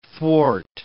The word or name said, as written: thwart